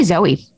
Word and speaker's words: Zoe: Is it pronounced Zoe or Zoe? – It's actually Zoe.